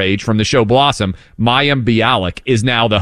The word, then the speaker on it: Mayim Bialik: Mayim Bialik? Is that right? – Yeah.